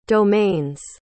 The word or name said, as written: domains